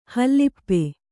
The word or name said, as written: ಹಲಪಿ